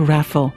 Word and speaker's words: Raffel: That's Sydney's new dean of St. Andrew's Anglican Cathedral, on his father's knee, learning to read, Kanishka Raffel. I asked him how he pronounced his last name, and he said, like falafel.